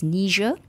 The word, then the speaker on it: Niger: It's Niger.